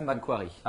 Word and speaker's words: McQuarrie: McQuarrie.